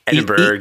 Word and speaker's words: Edinburgh: How do you say Edinburgh? – Edinburgh, right? – Edinburgh.